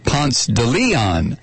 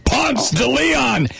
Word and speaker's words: Ponce de Leon: And the street is named Ponce de Leon, and it's spelled Ponce de Leon, just like the French explorer. But here in Georgia, it's pronounced Ponce de Leon. That's the way they pronounce it, Ponce de Leon.